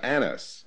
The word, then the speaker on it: Annas: Annas.